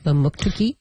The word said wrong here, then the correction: Mamotokuti: No, Mamotokuti. – Mamotokuti. Oh, I missed something in there.